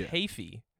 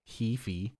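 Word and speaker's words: Heafy: His last name is Hafey. – Oh, is it Hafey? – Hafey. – I mean, that makes sense with how it's spelled, but I guess, you know, Hefe. – But I think most people, I think pretty much everybody says Hefe.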